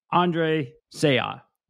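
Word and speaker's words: Andre Sayegh: Andre Seah. Welcome, Mayor. – You pronounced that perfectly. Thank you. It's like you practiced all morning in the mirror.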